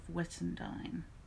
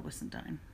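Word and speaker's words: Whissendine: It's the village that I grew up in, it's the name of Wittendine, which nobody can ever spell. Even people who lived in England couldn't spell Wissendine. It's quite complicated. It has a silent H.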